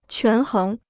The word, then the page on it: 权衡: quán héng